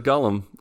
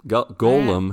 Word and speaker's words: golem: There's a golem. Golem. Man. Dang it, I said it wrong. That's a golem.